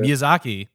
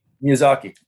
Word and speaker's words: Miyazaki: The Japanese animator Miyazaki. – Miyazaki.